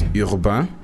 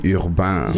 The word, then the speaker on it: Urbain: Well, Urban is actually my real name, yeah. – That's sick. – But originally it's spelled with an I, so it's Urbain. It's French.